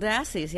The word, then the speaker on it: Dassies: Is that how you say it? – Little Dassies, yep.